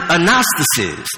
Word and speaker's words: anastasis: You pronounce it anastasis.